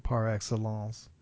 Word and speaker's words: par excellence: And the tip-off for pronunciation is that the word par is in front of it. That's the tip-off. This is the French-derived version of that, so you'll pronounce it par excellence.